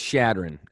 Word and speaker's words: Chadron: Chadron State, is that how you say it? – No, it's Chadron. Everyone calls it Chadron, so I just don't even really.